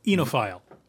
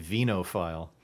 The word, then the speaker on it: oenophile: O-E-N-O-P-H-I-L-E. – Really? – Yeah. – I feel like it should be venophile. – You would think, but I believe it is enophile. O-E-N-O-P-H-P-H.